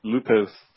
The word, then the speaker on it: Lupoth: It's spelled like Lupoath. Mm-hmm. It's pronounced Lupoth.